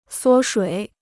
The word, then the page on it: 缩水: suō shuǐ